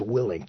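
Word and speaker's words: Willink: It's actually pronounced Willing, Jocko Willing.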